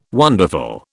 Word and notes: wonderful: British English

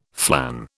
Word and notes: flan: British English

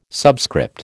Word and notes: subscript: American English

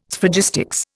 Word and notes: Sphragistics: Indian

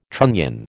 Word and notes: trunnion: American English

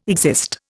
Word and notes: exist: Indian

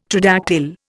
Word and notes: tridactyl: Indian

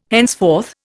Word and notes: henceforth: Indian

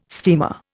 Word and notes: steamer: Indian

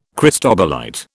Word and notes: cristobalite: British English

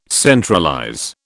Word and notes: centralize: British English

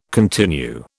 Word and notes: continue: British English

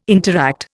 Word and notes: interact: Indian

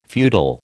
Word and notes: feudal: American English

